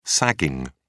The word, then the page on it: sagging: British English